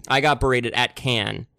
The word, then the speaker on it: Cannes: And it's not Cannes. – Oh. – It's not Cannes. There's a lot of different ways of pronouncing it, but people, it is Cannes.